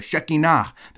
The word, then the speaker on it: Shekinah: That's the, shek- not the Shekinah, it's the Shekinah, the Shekinah glory cloud. I almost said Shekinah, but the Shekinah, you always want to put the emphasis on the last syllable, right?